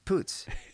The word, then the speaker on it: Poots: It's not pronounced M-O-G. But Imogen... – Mog. – How do you pronounce Imogen? Like Imogen Poots? – Potts? – Potts. – Imogen Potts? – Poots. Isn't there Imogen Poots?